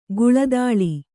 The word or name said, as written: ಕಳ್ಳದಾಳ